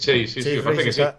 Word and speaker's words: Chase: No sé cómo se dice exactamente, sí, me parece que es Chase. – está, bien dicho, no es, no es un inglés de Oxford, pero está bien dicho.